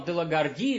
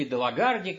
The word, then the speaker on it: Делагарди: генерал Делагарди или Делагарди, как это раз, по-разному ставят ударение, с новгородцами он подписывал на эту тему даже договор.